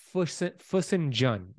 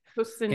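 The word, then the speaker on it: fesenjan: She makes this traditional Persian dish called Fusenjun. That's how you say it. – Fusenjun.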